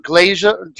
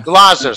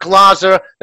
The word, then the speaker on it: Glaser: And I also have Scott Glaser. Scott, did I pronounce that right? Scott Glaser. – That's Glaser. Yeah. – Glaser. Scott Glaser, the National Retail Sales Manager.